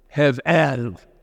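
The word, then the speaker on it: hevel (הבל): And I'm not a Hebrew scholar, but I looked it up to get the correct pronunciation, and it's hevel. It's like you're clearing your throat.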